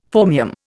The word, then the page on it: phormium: Indian